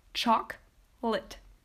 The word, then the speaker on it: chocolate: So even though it has like, I don't know, more than four syllables, it is actually only pronounced with two syllables, and that is chocolate.